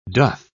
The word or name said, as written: doth